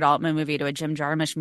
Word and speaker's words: Jarmusch: Fun thing about me is that this year, 2024, I have finally internalized that it's pronounced Jarmusch and not Jarmusch, which I've been saying most of my life.